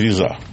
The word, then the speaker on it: Сириза: Я просто хочу сказать, что, ну, во-первых, нам подсказали наши специалисты по Греции, что правильно называется Сириза. – Сириза. – Сириза. – Да, Сириза. – Ну, вот. То есть на ударение на первом слоге.